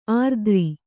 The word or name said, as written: ಆರಿದ್ರ